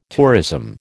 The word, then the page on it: tourism: American English